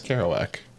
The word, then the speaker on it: Kerouac: Kerouac. – No. I actually YouTubed it last night. – Oh, did you? – Yeah. – Jack, let me see. – It's... – Oh, no, it's Kerouac. Jack Kerouac.